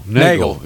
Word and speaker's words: Neagle: Rhymes with beagle. I think a beagle dog when I look at it. – Neagle? – Yeah. – Nagel. Yes.